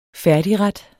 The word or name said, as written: færdigret